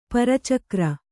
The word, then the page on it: ಪರಚಕ್ರ: para cakra